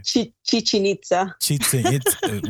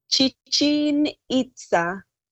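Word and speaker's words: Chichén Itzá: Hope I said that right. – Chichen Itza. – Wait, say it again. Oh, man. – Chichen Itza. – Chichen Itza. – Okay. Perfect.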